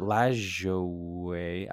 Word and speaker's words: Lajoie: It is Lajoie, right? – Correct. Yes. – So it is like good luck, basically, trying to figure out how that's pronounced. But I've always said Nap Lajoie. – Yeah. – But Lajoie, I guess it kind of makes sense, because it's all those vowels, like, unless they're diphthonged or something. – Right. – You got to pronounce all the vowels, right? Lajoie.